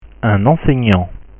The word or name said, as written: un enseignant